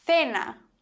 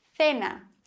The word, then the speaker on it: cena: Das heißt, C vor E und I würde man aussprechen Cielo, Cielo, der Himmel. Oder Cena, das Abendessen, Cena.